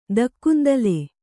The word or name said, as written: ದಕ್ಕುಂದಲೆ